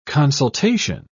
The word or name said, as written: consultation